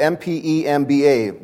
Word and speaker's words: Mpemba: And that's that effect is called the, I don't even know how you say it, MPEMBA, Mpemba effect.